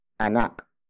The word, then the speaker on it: anak: It is the beneficiary marker, indicating the action is done for the benefit of anak. Anak. Anak. Anak is spelled as A-N-A-K.